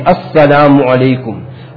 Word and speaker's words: Assalamu alaikum: And also very important, Hazrat teaches us also the hamza in the assalam, that we are very negligent. We don't take out the hamza properly. Assalamu alaikum. It is very important that we pronounce this hamza properly.